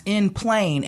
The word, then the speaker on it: en plein air: E-N-P-L-E-I-N. That's in plein. And then air is spelled is spelled A-I-R, but this is what, French? – Yes. – It's a French spin on it.